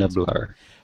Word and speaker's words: Nublar: I don't know how you pronounce the name of the Jurassic Park island. – Nublar.